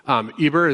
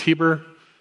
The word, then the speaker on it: Eber: Eber is sometimes pronounced Heber. It's where we get the word Hebrews.